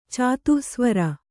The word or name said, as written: ಚಾತುಃಸ್ವರ